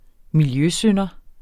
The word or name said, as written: miljøsynder